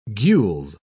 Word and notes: gules: British English

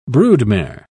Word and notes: broodmare: British English

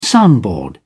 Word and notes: soundboard: British English

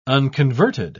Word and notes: unconverted: British English

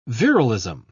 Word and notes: virilism: British English

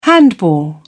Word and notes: handball: British English

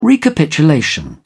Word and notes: recapitulation: British English

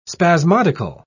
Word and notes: spasmodical: British English

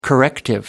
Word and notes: corrective: American English

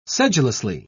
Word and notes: sedulously: British English

